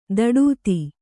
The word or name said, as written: ಧಡೂತಿ